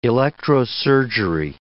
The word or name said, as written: electrosurgery